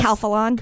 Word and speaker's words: Calphalon: Wait, Califon or how do you say it? – Yes. Calphalon.